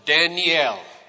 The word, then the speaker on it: Daniel: The name Daniel has 3 syllables. If you pronounce it, Daniel. And each one of those syllables in Hebrew has a meaning. Dan means judge. A little E sound in the middle is the Hebrew word for my. And El is the Hebrew word for God.